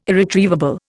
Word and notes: irretrievable: Indian

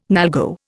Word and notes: NALGO: Indian

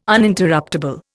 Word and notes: Uninterruptable: Indian

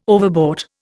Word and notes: overbought: Indian

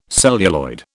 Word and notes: celluloid: British English

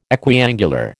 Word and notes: equiangular: American English

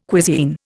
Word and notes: cuisine: Indian

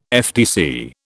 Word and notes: FTC: British English

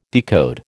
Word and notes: decode: American English

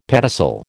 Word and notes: pedicel: American English

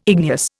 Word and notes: igneous: Indian

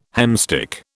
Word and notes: hemstitch: British English

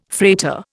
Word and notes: freighter: Indian

